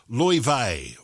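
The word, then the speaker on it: Laufey: Apparently, it's pronounced Lofay or Lofay.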